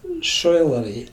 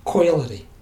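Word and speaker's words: Coilarigh: It's no spelt soilery. It's C-O-I-L. A-R-I-G-H. Soilery. It's coilery. But it's hard. C is always pronounced in Gaelic hard.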